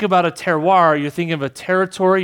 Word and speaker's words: terroir: So it's pronounced terroir. It's a French word.